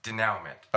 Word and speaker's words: denouement: But all right, seriously, how do you say it? – Denouement. – Okay, so denouement.